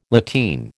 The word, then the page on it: lateen: American English